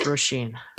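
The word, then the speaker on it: Róisín: And I believe that name is pronounced Roisin.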